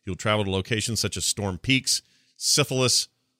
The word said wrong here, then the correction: Silithus: Oldwar. – Silithus. – I always say it wrong.